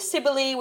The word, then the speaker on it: Cybele: Now, Cybele is probably not the way the Romans actually pronounced Cybele. They probably pronounced it with a K. I'm still going to go with Cybele, which is going to drive Heather crazy.